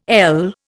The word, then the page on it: ell: Indian